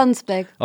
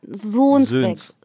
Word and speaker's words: Sonsbeck: Aus Sonsbeck. Aus Sonsbeck. Was viele immer, gesagt, immer falsch aussprechen, nämlich Sohnsbeck. Sohnsbeck.